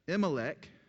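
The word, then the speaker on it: Elimelech: Imelech there we go.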